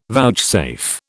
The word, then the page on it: vouchsafe: British English